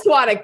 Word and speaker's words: Svatek: Please welcome Liz Sawadik. Did I say it right? I mean, almost. Okay. Liz Svatek.